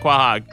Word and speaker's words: Quahog: Quahog. Is that how you pronounce it? Quahog. – Quahog.